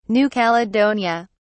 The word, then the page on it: New Caledonia: British English